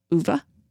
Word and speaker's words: Ove: Like, you guys haven't heard it yet, but we'll talk about a man called Uva. Apparently, that's how you say it. Mm-hmm. – Or I'll sit here and say a man called Ove.